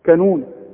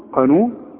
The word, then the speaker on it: قانون: وعندك بقى في الكلمات البلد بتاعتنا بقى المتداولة، عمرك ما تقول على القانون كانون، تقول كانون، قانون، وتقول قابوس، ما تقولش ايه، هتقول كابوس، قابوس، قرار، ها؟